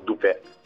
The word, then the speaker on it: Dupé: E noi facendo l'abbreviazione, facendolo tornare che suonasse un pochino meglio, abbiamo chiamato Dupé con l'accento sulla E.